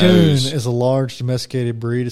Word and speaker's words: Maine Coon: There's no way that's how you pronounce that name. – No, it is. – A Maine Coon. – It's two words. – Like a man coon. – Maine, like the state.